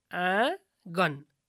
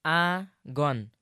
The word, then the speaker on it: आँगन: गुरु, आँगन भन्नुपर्नेमा आँगन अनि अधिकांश भन्नुपर्नेमा अधिकांश भने। – हो त, यसलाई आँगन आँगन र अधिकांश अधिकांश भनेर उच्चारण गर्नुपर्छ।